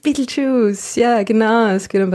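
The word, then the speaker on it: Betelgeuse: Name in Wirklichkeit, aber auf Englisch spricht man ihn tatsächlich Beetlejuice aus.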